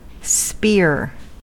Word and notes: spear: American English

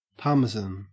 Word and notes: parmesan: Australian English

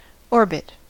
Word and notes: orbit: American English

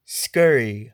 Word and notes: scurry: American English

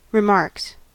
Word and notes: remarks: American English

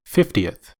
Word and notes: fiftieth: American English

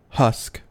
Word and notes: husk: American English

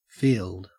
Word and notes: field: Australian English

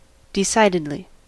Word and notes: decidedly: American English

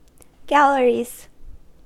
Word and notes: galleries: American English

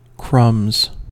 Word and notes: crumbs: American English

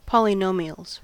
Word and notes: polynomials: American English

